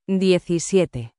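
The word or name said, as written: diecisiete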